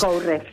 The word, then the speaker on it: Courrèges: Acá me manda, mira, un mensaje Marta, que me lo manda por Instagram, que me dice Courrèges, porque se escribe Courrèges, en francés. – Courrèges.